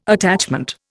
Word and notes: attachment: Indian

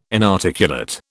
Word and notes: inarticulate: British English

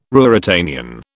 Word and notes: Ruritanian: British English

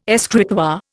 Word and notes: escritoire: Indian

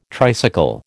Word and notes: tricycle: American English

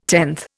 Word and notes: tenth: Indian

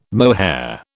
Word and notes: mohair: British English

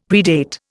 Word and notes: predate: Indian

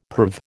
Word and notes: perv: American English

